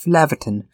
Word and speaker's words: Flavortown: I mean, it's spelled like Flavortown, but it's pronounced Flaverton because Flaverton is a much fancier name, even though it's not really a fancy place at all.